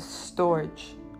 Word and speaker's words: storge: The third one we have is storge. I believe that's how it's pronounced. S-T-O-R-G-E. And this is the love that parents have for their children, right?